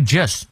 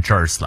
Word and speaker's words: just: 这个 just 注意别卷舌念成 jers 了啊。